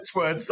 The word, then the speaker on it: sword: Okay, take the W out entirely. Sword. – Sword. Oh, yeah. – Yeah, there you go. You just have to ignore the W, okay?